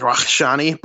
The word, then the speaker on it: Rakshani: You know, it's a little more, you know, he's Iranian, so it's more like Rakshani, but still Rhett Rakshani would be more of the American pronunciation.